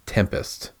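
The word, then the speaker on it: 7empest: It's something called Tempest, but it's spelled 7, so the number 7, and then E-M-P-E-S-T, but it's pronounced Tempest.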